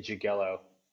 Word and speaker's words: Jagiello: It's pretend the I's not there. You can say Jagiello. That's just fine.